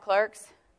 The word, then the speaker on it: clerks: You know why you're called clerks? Because it rhymes with work.